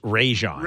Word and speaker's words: Rajon: So Rajoni, whatever you want to pronounce his name, because I've heard it pronounced a million ways, but I know that it's Rajon.